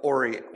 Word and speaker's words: Horry: Isn't it Horry? – Horry. I think it is pronounced Horry, Horry, and the H is sort of silent, but it's kind of one of those things where I'm that way with names, even going all the way back.